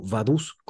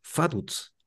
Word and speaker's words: Vaduz: Pero deben saber que en alemán la V chica se pronuncia como F y la Z como TS. Entonces la pronunciación correcta es Faduz.